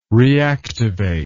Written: reactivate

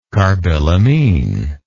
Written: carbylamine

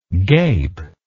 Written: gapy

